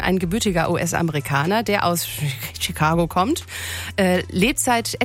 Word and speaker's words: Chicago: Obwohl normalerweise CH auf Englisch CH ausgesprochen wird, eigentlich ist es Chicago, als ob es SCH wäre.